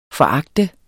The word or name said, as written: foragte